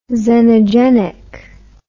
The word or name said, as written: xenogenic